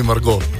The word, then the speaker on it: Margot: Pa smo pa rekli mini Margo. In pa malo smo ga pofrancozili, tako da se T ne izgovarja.